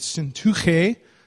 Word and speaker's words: Syntyche: I plead with Euodia and I plead with Syntyche, that's the real pronunciation, to agree with each other in the Lord.